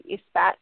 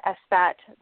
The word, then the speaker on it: Esbat: And from a very traditional witchcraft perspective, there's the Isbat, the Esbat, the Isbat, however you want to pronounce it, E-S-B-A-T.